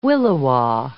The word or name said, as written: williwaw